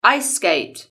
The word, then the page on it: ice skate: British English